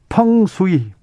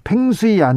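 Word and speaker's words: feng shui: 펑수이, 펑수이입니다. 팽수이 아니고요. 네, 펑수이 이 중국 중국말을 중국말을 그대로 영어로 써가지고 펭수이 아닙니다.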